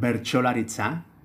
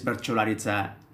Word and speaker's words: Bertsolaritza: And so the art, this improvised poetry, is called Bertsolaritza, which literally means poetry. – Okay. – You know, so for Basques, in Basque, poetry is Bertsolaritza, is improvised poetry.